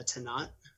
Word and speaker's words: Tannat: I think it's a Tannat. – Yeah, that's correct. Great. That's right. – Yeah. – Yeah, but, I love Tannat.